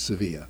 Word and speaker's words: Sevilla: It's called Sevilla or Seville. We know it is Seville, but in fact it's said or pronounced Sevilla.